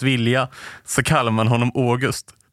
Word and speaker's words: August: Hon menar också att det framgår i brev och berättelser från författarens omgivning att han vill uttala som Å. Och de bevisen skulle jag vilja se. För hon är den enda som har läst de breven där han är så tydlig med att det ska vara August.